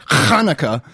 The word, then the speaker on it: Hanukkah: Chinooka, or as we would refer to it, Hanukkah, the Festival of Lights. Thank you very much. – Is that how you say it? – Yes, Hanukkah. Now,